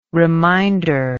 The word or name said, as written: reminder